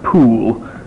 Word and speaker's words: Powell: I will continue to call him Edward Powell because that's the way we in America say it. It's probably pronounced Poole, but you can live with that. It's Powell in the card catalog.